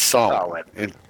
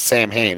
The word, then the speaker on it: Samhain: In paganism, in general, it's Samhain. But in the Ghostbusters universe, it's absolutely... Like, that character's name is Sam Hain. It's always been pronounced Sam Hain.